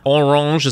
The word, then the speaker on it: Orange: So for this particular deal, what we've reported is that Orange, is that right? Is my French pronunciation correct on that? – Yeah, that's perfect.